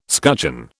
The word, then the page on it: scutcheon: British English